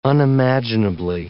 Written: unimaginably

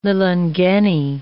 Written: lilangeni

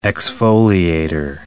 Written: exfoliator

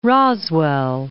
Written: Roswell

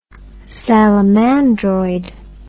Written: salamandroid

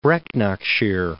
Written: Brecknockshire

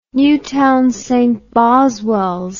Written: Newtown St Boswells

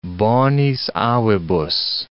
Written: bonis avibus

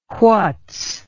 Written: whats

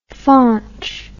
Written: faunch

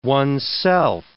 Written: one's self

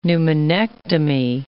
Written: pneumonectomy

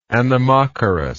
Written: anemochorous

